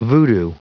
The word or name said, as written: voodoo